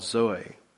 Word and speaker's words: Zoe: It's called Zoe. It's Z-O-E, Zoe.